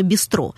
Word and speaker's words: бистро: И ударение, естественно, для французов поставили на последний слог, а то куда же? Французы на другие слоги ударения не ставят. Вот. Ну и вместо «быстро» получилось это самое «бистро». Может быть и так.